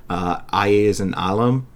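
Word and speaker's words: Alles in Allem: So this is the new Einstürzen Neubauten album, Aye is an Alam, which I believe that's the pronunciation of the title, because Blixa Bargeld, I'm pretty sure that's what he says in it. That's how he pronounces it in the title track.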